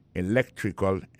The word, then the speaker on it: electrical: Electrical engineering. Ça veut dire accent tombé dans electrical là, tombé sur deuxième syllabe là. Electrical engineering. C'est sur deuxième syllabe là. Elect là, plus appuyé. Electrical engineering.